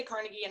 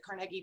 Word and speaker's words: Carnegie: I say Carnegie, and I think some other people might pronounce it Carnegie,